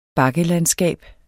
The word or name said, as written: bakkelandskab